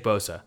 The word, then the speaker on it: Bosa: Um, it rhymes with Sosa. It does. Yeah. It does. – Yeah. – Yeah. – Um, it, it does. It's Nick Bosa.